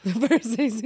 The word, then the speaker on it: Versace: Versace? – Versace.